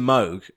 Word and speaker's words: Moog: Then Mr. Moog, which is how you actually say his name.